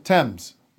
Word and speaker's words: Thames: How do you pronounce that river anyway? – Thames. – Yeah. – Why is the H there? – John?